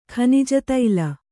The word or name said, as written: ಖನಿಜತೈಲ